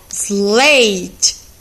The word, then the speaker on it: slate: Depois, nós temos o exemplo slate, que se escreve slight. Slight, S-L-A-I-T. E a gente pronuncia slate, ok?